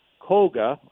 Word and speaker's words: coger: However, one reason it was scrapped by Schlitz was reportedly because Baxter's word for grab, coger, I hope I'm saying that right, C-O-G-E-R, is slang for sexual intercourse.